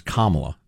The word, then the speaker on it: Kamala: Said, here's how you remember how to pronounce Kamala. It starts like comrade.